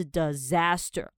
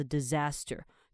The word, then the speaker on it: disaster: 这个 disaster 啊，它第一个 s 其实发音是 z 的音，所以不要念成 disaster，是 disaster。 OK，灾难啊，那你就可以说哦，it was a disaster。